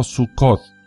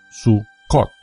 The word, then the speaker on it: Sukkot: Sukkot, pronunciado Sukkot o Sukkot, es un festival judío que comienza en el día 15 del mes hebreo de Tirri, aproximadamente entre septiembre y octubre.